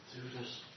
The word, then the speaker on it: Theudas: How would you pronounce that? Do you know? Theudas? – Theudas, something like that. – Okay. Yeah, that's right.